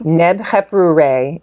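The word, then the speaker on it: Nebkheperure: So we'll say it again slowly. – Yes. – Nebheperre is the way we pronounce it. – We, as Egyptologists, would pronounce it reading the signs. And then in the Akkadian. – In Akkadian, it's Nebheria. – Yeah. – So it's a little bit more crunched together.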